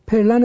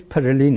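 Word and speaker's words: Pèlerin: حالا پرلن رو در فارسی میگن ولی تلفظش پرلین هست،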